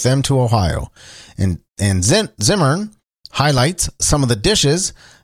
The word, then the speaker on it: Zimmern: Host Andrew Zimmer, Zimmern, is that how you say it? I always thought it was Zimmerman. Andrew Zimmern will take a tour of Cincinnati's culinary icons on the upcoming episode.